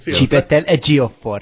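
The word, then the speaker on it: Chiwetel Ejiofor: Óriási sztárparádé van a filmen, Woody Harrelson, Kate Winslet, ez a csávó, aki, sőt tudom, ki, ki, hogy kell kiejteni a nevét. – Csibettel Egyiofor. Csibettel Egyiofor, igen.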